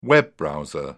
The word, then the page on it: web browser: British English